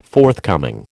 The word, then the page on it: forthcoming: American English